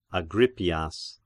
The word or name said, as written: Agrippias